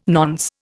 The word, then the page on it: nonce: Indian